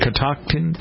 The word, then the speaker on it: Catoctin: Catoctin? – Yeah. – Is that how you pronounce it? C-A-T-O-C? – Yeah.